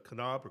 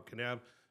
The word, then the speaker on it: Kanab: Around that same area, a little bit to the east, a couple hours to the east, is a place called Kanab or Kanab, depending on where you're from or how you pronounce things, but it's K-A-N-A-B, okay, Utah.